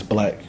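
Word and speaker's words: 6lack: It's Black. – It's Black with a six mm-hmm. in front.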